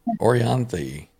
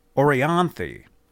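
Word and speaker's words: Orianthi: Yeah, Laz was there with, how do you say her name? – Orianthe. – Orianthe. That's right.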